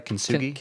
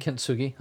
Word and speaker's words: kintsugi: The kintsugi art. Did I say that right? Kintsugi? – Kintsugi. Uh-huh. – Kintsugi, yeah.